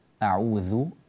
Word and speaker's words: أعوذ: وانتبه إلى كلمة أعوذ، أعوذ هذه بحرف الذال، وحرف الذال لابد أن نخرج ألسنتنا في نطقه أذ، ولا نلصق الأسنان بعضها في بعض فلا ينطلق معنا اللسان بالصوت أعوذ، ولا نقول أعوذ، فانتبه لهذا.